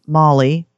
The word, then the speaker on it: Molle: So, and now my other show and tell is we were talking about it's actually pronounced Molly, which, CPO informed me of that.